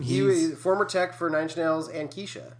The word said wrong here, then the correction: Kesha: It's pronounced Kesha.